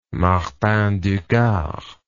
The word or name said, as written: martin du gard